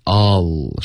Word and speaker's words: All: All kids are embarrassed by their parents. – All 的这个发音是不对的，别动嘴唇。 All 是不带嘴唇任何动作的一个发音。OK。